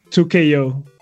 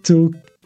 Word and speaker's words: 2XKO: Porque vi por ahí que era 2KO, o como que la pronunciación era 2KO, como que te comías la X, ¿no? Pues, pues dijo Riot en X que se pronuncia como se, como se deletrea.